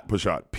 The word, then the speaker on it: Peshat: No, Pashat. P-A-S-H-A-T. That's what that's the way I spell it. Or P-E-S-H-A-T. Same thing. – Right. – Okay. The second one is Remez. Mm-hmm. R-E-M-E-Z.